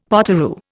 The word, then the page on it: potoroo: Indian